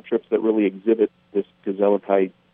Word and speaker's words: gezelligheid: That's how you translate it, you know, and it's pronounced gezelligheid. Go on, say it.